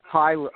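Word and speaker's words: Huyler: Chauncey, as many times as we spoke, because you call me Professor, not by my last name, it's Hyler, H-Y-H-U-Y-L-E-R, but pronounced as if the U wasn't in it.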